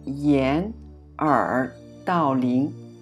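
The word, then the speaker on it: yen, er, dao ling (掩耳盗铃): Alors la prononciation, pinyin, c'est yen, er, dao ling, yen, er, dao ling.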